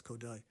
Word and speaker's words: Kodály: Yeah, well, and it's spelled Kodály, K-O-D-A-L-Y, but pronounced Kodály.